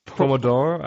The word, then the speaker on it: Pomodoro: You can set your Pomodorino. how do you pronounce it? – Pomodoro, I think that's Pomodoro. Yeah, I think you're Pomodoro. You're totally right. Pomodoro.